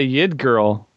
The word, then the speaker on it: Yggdrasil: I always said this one, Yggdrasil, the world tree, from page 334. – Right, just like Yggdrasil. – No, say it again. Yggdrasil? – I always say Yggdrasil. – Yggdrasil.